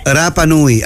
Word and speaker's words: Rapa Nui: No, lo que pasa es que allá, por ejemplo, tú escuchas la palabra Rapa Nui. No Rapa Nui. ¿Se entiende? – Ah, ya. – Rapa Nui. Así se pronuncia, o sea, como corresponde.